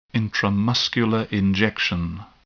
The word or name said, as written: intramuscular injection